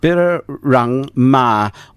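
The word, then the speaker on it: Birrarung Marr: So come along, everyone, to Midsummer Sunday down at Birramatta. How to pronounce it? – Birrarung Ma.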